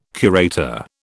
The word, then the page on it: curator: British English